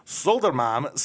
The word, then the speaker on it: Södermalm: They are from Södermalm, Sweden.